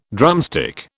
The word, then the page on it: drumstick: British English